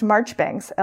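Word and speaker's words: Marjoribanks: Oh, pronounced Marchbanks, LOL. Miss Marchbanks is apparently how you say that, because British.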